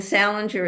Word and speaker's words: Salenger: And Salenger is spelled S-A-L-E-N-G-E-R.